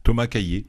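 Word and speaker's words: Cailley: Comment vous prononcez le nom du réalisateur ? – Thomas Cayley. – Voilà, Thomas Cayley, tout simplement.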